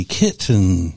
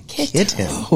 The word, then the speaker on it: kitten: And you have to say it like you stress the T. Kitten. – Kitten.